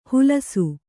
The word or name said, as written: ಹುಲಸು